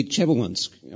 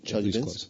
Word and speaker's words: Chelyabinsk: The Chelyabinsk, am I pronouncing it? – Chelyabinsk? – Yeah.